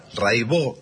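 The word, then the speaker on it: Raybaut: Cata Raibó. Se escribe Raibaus, métanse, búsquenla, Rai con Y, Baut con B larga. Catalina Raibaut, Raibó se dice, y bueno, cotidiano son 10 canciones, hay un documentalito muy lindo.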